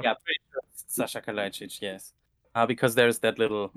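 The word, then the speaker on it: Saša Kalajdžić: Yeah, so first and foremost, if I'm not mistaken, the correct pronunciation would be something like Sasha Kalajdzic. – Okay. – hope that's correct. – It's Sasha, not Sasha.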